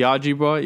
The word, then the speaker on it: Ojibwa: The Ojibwa. Is that how you say it? – Ojibwa? – The Ojibwa.